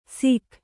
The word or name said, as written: ಸಿಖ್